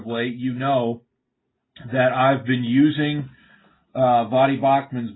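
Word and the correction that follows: Baucham: book, Vadi Bochum, I guess it'd be the correct pronunciation.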